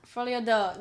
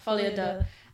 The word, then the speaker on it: Folie à deux: Folie à deux. That sounds... Deux? Yeah, un, deux, trois. Yeah. – Yeah. – Folie à deux.